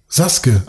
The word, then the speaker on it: Sasuke: Sasuke. Ah, das U äh, spricht man nicht mit.